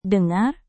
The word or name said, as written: dengar